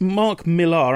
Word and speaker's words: Millar: Is that how you pronounce his name? – I think so. It's Mark Millar. I'm pretty certain of it, yeah.